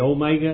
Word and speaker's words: Omega: And then Omega. Omega. That's a long O. The Omicron is a short O. The Omega is a long O.